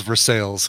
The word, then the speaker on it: Versailles: It's spelled the same as Versailles in France. – Yeah. – Where is Versailles? Because it's stupid that it's printed in the U.S. It's pronounced Versailles.